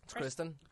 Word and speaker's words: Kristen: Like, but yeah, she's like, Kirsten Wiig is like the one who sort of thinks she has it more together. How do you pronounce that? – It's Kristen.